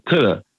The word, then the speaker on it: Tada: So once I was done making music and I started DJing, I just kept my name Tudda. And like when I print cards or people read my name, they would say DJ Tada, and because it's spelled T-A-D-A, and I didn't like it.